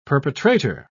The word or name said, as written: perpetrator